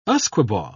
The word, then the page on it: usquebaugh: British English